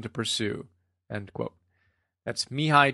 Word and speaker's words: Mihai: According to Martin Seligman, the author of Authentic Happiness, and you can see the notes on that, it's Mihai is his first name, Csikszentmihalyi.